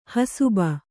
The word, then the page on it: ಹಸುಬ: hasuba